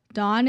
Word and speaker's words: Davvn: Full disclaimer for myself, Dawn is spelled D-A-V-V-N, and I definitely did not know it was pronounced Dawn until I actually interviewed them and they corrected me.